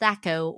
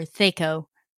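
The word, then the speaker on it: THAC0: It's pronounced Thaco or Thaco.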